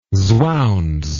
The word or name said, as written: swounds